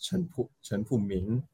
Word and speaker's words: Chen Pu Ming: It's actually pronounced Chen Pu Ming. Ming as in like M-I-G.